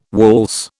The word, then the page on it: waltz: British English